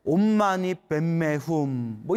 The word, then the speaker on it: 옴마니 반메훔: 우리 한국에서는 옴마니 반메훔이라고 발음하고 티베트 발음으로는 옴마니 뱀메훔 뭐 이렇게 발음을 한다고 합니다.